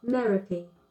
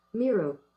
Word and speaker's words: Merope: Merope. – Rhymes with therapy. Okay, wait. I'm going to switch it to the American pronunciation. – Miro.